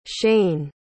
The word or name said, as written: shaine